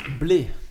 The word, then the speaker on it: Bled: et alors, euh, ça se prononce Bled, mais ça s'écrit B-L-E-D.